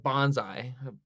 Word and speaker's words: bonsai: As a fan of bonsai is what I call it. That's how you pronounce it.